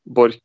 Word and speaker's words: Borch: So the Norwegian way of saying it is Kjetil Bork.